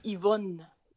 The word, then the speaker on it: Yvonne: That's how the French would pronounce it. Oui, Yvonne. All right, well, it's better than the Americans. Yvonne. It's like, no, my Yvonne. Name is not supposed to rhyme with yogurt.